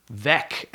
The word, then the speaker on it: Veeck: According to his autobiography, Vec, as in wreck, Ah.